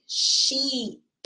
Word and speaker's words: sheep: Or do you guys say sheep? Sheep. Remember, this double E pronounces the E, and you do it when you make your mouth smile like a bit.